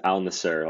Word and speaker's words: Al Nassr: Al Nazir. I believe it's Al Nassr. Like it's Al- literally spelled A L space N A S S R. – Al okay, Nasser. Al Nassr.